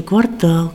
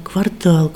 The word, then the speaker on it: квартал: Кажется, вот мне кажется, что, я не знаю, все 60 лет, сколько я вообще живу, все время поправляют, что квартал, квартал, квартал.